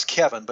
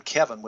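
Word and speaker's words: Cevin: My first name is Kevin, but it's spelled C-E-V-I-N. I'm Kevin with a C, okay? Last name is Ormond, O-R-M-O-N-D.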